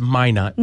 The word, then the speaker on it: Minot: It's Minot.